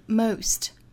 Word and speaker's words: most: The most dangerous, 这里的 most 的发音我们早读经常见哈，字母 O 在英音里很多时候会发成 O, 那这里呢是 most, most.